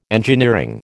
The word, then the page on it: engineering: American English